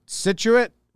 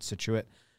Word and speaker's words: Scituate: Scituate, Rhode Island. Is that how you say it, Josh? Trying to scroll up and read Josh's comment to see if I've... Yeah, that's how you pronounce it, he said.